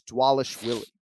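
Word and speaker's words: Dvalishvili: The V's are pronounced as W's, so it's Dwalish Willie.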